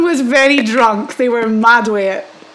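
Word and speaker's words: mad wae it: If you saw someone and they were mad way it. – Spell it M-A. – So M-A-D and then W-A-E and then I-T. Three words.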